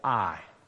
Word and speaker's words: Ai: It's spelled Ai, but it's called Ai.